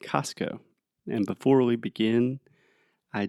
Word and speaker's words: Costco: So technically, it would be like cost, like the price, and then co, like company. – Company. – So I imagine the more correct way to say it would be Costco so hard, Costco.